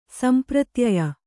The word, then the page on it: ಸಂಪ್ರತ್ಯಯ: sampratyaya